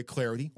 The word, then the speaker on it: Clardy: That way you're not, I mean, you can constantly check my Twitter at Troy Clarity. Last name is spelled C-L-A-R-D-Y.